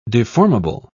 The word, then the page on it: deformable: British English